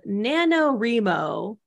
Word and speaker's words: NaNoWriMo: So there's this thing called NaNoWriMo, which is spelled N A N O W R I M O. It stands for National Novel Writing Month.